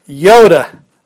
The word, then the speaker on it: iota: So, do you say it iota or do you say it iota? Well, Erasmian pronunciation would be iota, and I tend to, when I get to talking quickly, I tend to say Yoda, like the Star Wars guy, because it's iota, iota.